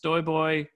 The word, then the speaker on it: Stojboj: My handle tends to be it's STOJBOJ, and it's actually pronounced Stoyboy, because my name is weirdly pronounced and weirdly spelled. STOJ is pronounced Stoy.